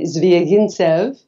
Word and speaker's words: Zvyagintsev: From Andrei Zvyagintsev, who made Leviathan.